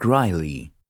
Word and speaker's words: Reilly: Mark Riley. – Would you be able to spell that for me? – R-E-I-L-L-Y.